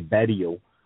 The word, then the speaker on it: Berrio: My last name is actually Berrio with the I-A-U-L-T at the end of it. So right. – I just changed the pronunciation of it for English so that it's easily pronounced and, you know, and understood.